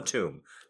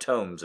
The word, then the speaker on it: tomb: Probably just Tomb of Terror and not Tombs of Terror. And definitely not Tomes of Terror, which is what I was saying. Like I wasn't pronouncing the B at all.